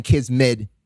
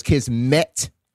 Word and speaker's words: kismet: I'm saying it wrong. I guess I'm saying kismet, but it's kismet. – Kismet. Kismet. No, kismet.